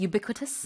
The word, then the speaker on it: ubiquitous: Would you understand that as ubiquitous? – No. – The stress is on the b. 여기에 스트레스가 있어요. 두 번째 음절.